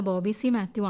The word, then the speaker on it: ബോബി സി മാത്യു: UK കുമാരിന്റെ തക്ഷൻകുന്ന് സ്വരൂപം വായിക്കുന്നത് ബോബി സി മാത്യു ആണല്ലോ. എന്നാൽ അനൗൺസർ പലപ്പോഴും ബോബി എന്ന് മാത്രമാണ് പറയുന്നത്. മാത്രമല്ല, ചിലപ്പോഴൊക്കെ ഉച്ചാരണം ബാബു എന്നും കേൾക്കുന്നുണ്ട്. അതുകൊണ്ട് വായിക്കുന്നത് ബോബി സി മാത്യു.